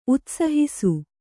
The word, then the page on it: ಉತ್ಸಾಹಿಸು: utsāhisu